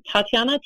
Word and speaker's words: Tatiana: So my first name is Tatiana, T-A-T-I-A-N-A. Last name, T as in Tom, S as in Sam, O-I-R.